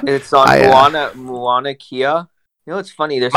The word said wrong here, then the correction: Mauna Kea: Mauna Kea. You pronounce it Mauna Kea.